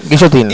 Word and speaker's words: Guillotin: A ver la pronunciación. – Guillotín. que no con Mariano para. Joseph Guillotin Guillotín se llama el Joseph Guillotin. Guillotin.